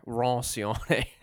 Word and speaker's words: Roncione: It's Dejana Roncione, I think is her name. She's Italian. I'm sure I'm mispronouncing that. – I think you added a little bit of your French in there. Roncione.